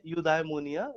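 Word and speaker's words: eudaimonia: तो कहते हैं यूडाइमोनिया, which means it's a Greek word.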